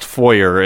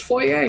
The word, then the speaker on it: foyer: It is actually pronounced foyer. It's just every time it's been said by Ross, which is why it's foyer. – It's a French word. It's foyer.